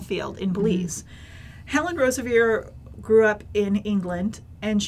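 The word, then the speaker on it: Roseveare: and it said Rosa Veer, as in deer, not Veery.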